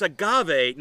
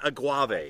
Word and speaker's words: agave: So it's agave, not aguave.